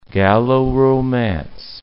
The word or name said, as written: Gallo-Romance